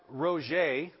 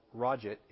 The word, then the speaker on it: Roget: It happened in the 1850s that Roget, I think is how you pronounce this man's name, or Roget, if you don't want to be exactly right,